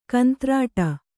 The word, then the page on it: ಕಂತ್ರಾಟ: kantrāṭa